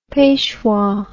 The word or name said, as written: peshwa